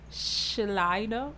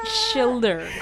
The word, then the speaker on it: Schilder: The phrase body image was first coined by the Austrian neurologist and psychoanalyst Paul Schleider. – His family's so mad, because I know they listen to this right now. I know that ain't how you pronounce his name. – Schilder.